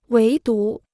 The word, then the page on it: 唯独: wéi dú